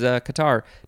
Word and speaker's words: catarrh: C-A-T-A-R-R-H. It's spelled not a typical way that we spell things here in English. It is Qatar.